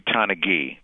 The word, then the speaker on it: Donaghy: Let's stop and take a closer look at Tim Donaghy. Is that how we're pronouncing his name? – No, it's actually Donaghy. Donaghy.